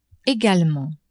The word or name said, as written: egalement